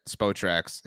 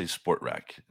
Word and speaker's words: Spotrac: So I'm looking at Spotrac's history here. They sort of. – I'm glad you said it correctly. Nice. A lot of people say Sportrac, and it's not Sportrac.